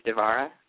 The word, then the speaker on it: Devarah: My Twitter account is at Devarah, which is spelled D as in dog, E-V-A-R-A-H. That's actually my name. My name is Devarah, but everybody just calls me D because Devarah is a little difficult for most people to pronounce.